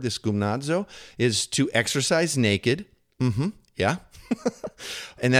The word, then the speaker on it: gymnazo: Now, the word translated exercise or discipline or train in the Greek, it's pronounced gymnazo. Okay, then that's probably not great because my Greek pronunciation is not super. But it's spelled, check this out, G-Y-M-N-A-Z-O, like gymnasium.